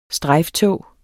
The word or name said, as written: strejftog